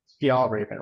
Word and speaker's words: Fjällräven: It's Swedish, right? So I think it's Fjällräven, right? So it means Arctic fox.